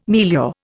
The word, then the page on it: milieu: Indian